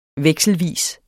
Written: vekselvis

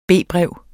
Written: B-brev

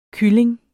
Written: kylling

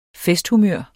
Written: festhumør

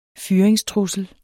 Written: fyringstrussel

